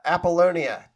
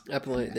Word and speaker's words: Apollonia: Apollonia. There it is. – Apollonia.